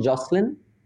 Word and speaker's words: Joslin: Is that how you pronounce it, Joslin? Atif? Yes, yes, that's correct. And